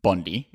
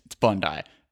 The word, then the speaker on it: Bondi: Okay, I know you know it's not pronounced Bondi. It's Bondi.